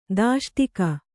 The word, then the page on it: ದಾಷ್ಟೀಕ: dāṣṭīka